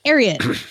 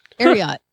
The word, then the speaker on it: Ariat: It's secretariat, but it's Ariat. Ariat. A-R, because his name is spelled S-E-C-R-E-T-A-R-I-A-T. – Well, that makes sense then.